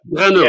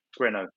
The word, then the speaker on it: Grano: It's like Grano. Yeah, like Renault with a G at the front. Grano.